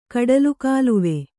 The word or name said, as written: ಕಡಲುಗಾಲುವೆ